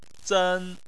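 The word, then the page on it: zen: tone 1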